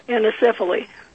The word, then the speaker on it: anencephaly: How do you pronounce that word, A-N-E-N-C-E-P-H-A-L-Y? – Anencephaly. – Anencephaly. Anencephaly. All right.